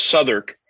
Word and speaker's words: Southwark: Yeah, you're not too far from, at that point, if you're down there, like from across the way to, is it Southwark? Where? – Yeah, it's pronounced Southwark.